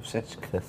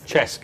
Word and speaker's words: Cesc: Is it correct pronunciation? Cesc? – Cesc. – Cesc. Oh, is that Cesc? I must be aware it's Cesc, as in Francesca.